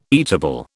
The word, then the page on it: eatable: British English